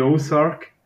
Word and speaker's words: Ozark: Ich erwähne sie jetzt gleich einmal, die Ozark. Habe ich es richtig ausgesprochen? – Du hast richtig ausgesprochen, ja.